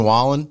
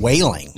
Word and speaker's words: Wallen: So Entertainer of the Year went to Morgan Wallen, mm-hmm. and they had Jeff Bridges up there, and he mispronounced his name. He called him Morgan Whaling.